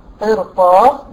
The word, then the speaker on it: قرطاس: لكن لو جه بعدها بقى حرف استعلاء، دي شذت هنا عن القاعده، تفخم في اربع كلمات اللي هما ايه؟ قرطاس. ده تسهيل للنطق يا بنات. تخيلي كده ان انا لما هرقق الريد بتاعت قرطاس، جربي قرطاس، هتعب، هفخم القاف وارجع ارقق الريد وارجع افخم الطاء، هتلاقيها مش سهلة على اللسان.